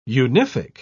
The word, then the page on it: unific: British English